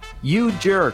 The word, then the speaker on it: U-J-3-R-K-S: You Jerk before that, but that is spelled U-J-3-R-K-S. The S is silent. The three is like an E.